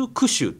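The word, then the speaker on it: 十九首: 静岡県に 19 の首って書く地名があるんですよ、えー、 19 種って読むんですけど、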